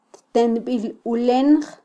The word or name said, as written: ttenbilulenj